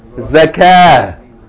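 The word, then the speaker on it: zakah (زكاة): Salah, zakah.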